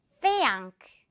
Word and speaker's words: think: Think. Think. 이렇게 메롱 발음 여러분 기억하면서 듣고 따라해보세요. Think.